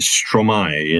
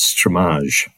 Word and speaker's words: Stromae: And his name is, we pronounce it Stromae in this country, but I think the Belgian pronunciation is Stromae.